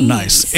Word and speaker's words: Nice: So this is how you pronounce the biscuit's name. The original name is not nice. It's Nice. – It's Nice. So it's Nice biscuits.